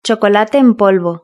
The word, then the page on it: chocolate en polvo: European Spanish